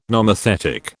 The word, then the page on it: nomothetic: British English